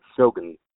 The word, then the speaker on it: Shogun: It's not goon like a goon squad. Just think G-N without the U. Shogun. Just go straight from the G into the N sound.